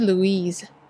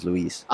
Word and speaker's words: Luis: It's Luis, 吧, 不是 Louise. – Maybe. – Louise is a girl, no? – No. I think the Spanish pronunciation is Luis.